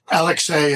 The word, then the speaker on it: Alexei: Leosha is her nickname for him, and Alexei is the correct pronunciation. He's Russian.